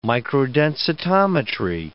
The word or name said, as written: microdensitometry